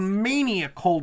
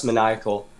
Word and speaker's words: Maniacal: Now the book you published recently is called Maniacal. Did I pronounce the name of that correctly? – Not quite. It's pronounced Maniacal.